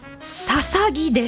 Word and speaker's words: 大角豆: ビーンの豆と書いてささぎです。大きな角の豆と書くので初見では大角図や大角豆、大角豆としか読めないのですが、なんとこれでささぎと読むのです。